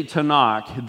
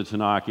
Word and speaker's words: Tanakh: It's some renderings of the Tanakh, it's got an H at the end, which is silent.